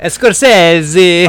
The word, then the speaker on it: Scorsese: I don't know how to actually pronounce his last name, but Scorsese.